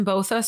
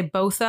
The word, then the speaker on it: Botha: And next week we have the Allison Botha story. And then, which is pronounced both, people say Botha and Botha. So I am not doing it incorrect. It is pronounced both ways, depending on where you live.